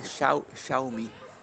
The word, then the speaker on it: Xiaomi: No, no último podcast que você falou da, daquela marca chinesa Xiaomi, é, eu, eu vi em algum lugar que a pronúncia correta é Xiaomi. É, é, é, como, se, é como se fosse, é, como se fosse o, o Mostre-me em inglês soa, o Xiaomi soa como o Mostre-me, entendeu?